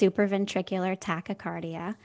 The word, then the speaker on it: supraventricular tachycardia: And you pronounced it correctly. It's supraventricular tachycardia.